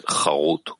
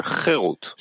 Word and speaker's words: חירות: Do not pronounce it חרות, carved, but rather חירות, freedom.